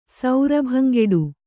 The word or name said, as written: ಸೌರಭಂಗೆಡು